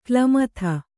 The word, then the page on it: ಕ್ಲಮಥ: klamatha